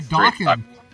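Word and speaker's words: Dokken: By Dawkins, rhymes with rockin', as the label says.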